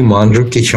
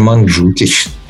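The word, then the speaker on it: Mandžukić: Вот он сказал, что он не Манджукич, а Манджукич.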